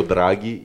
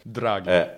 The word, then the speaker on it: Draghi: Because you were talking about Drago, if I'm saying that correctly, used to be involved in the EU. – Yeah, it's not Ivan Drago from the Rocky Balboa movie. it's Mario Draghi, yeah. – Draghi.